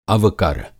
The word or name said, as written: अवकर